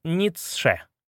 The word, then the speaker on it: Ницше: Фридрих Ницше. Произносится Ницше.